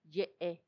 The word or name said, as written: yee